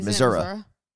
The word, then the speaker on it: Missouri: Also, and I'll probably mess this up, but it was like a while into my life when I realized people from Missouri don't call it Missouri. They don't like really pronounce the I at the end. It's Missouri.